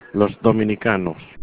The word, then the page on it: dominicanos: Spanish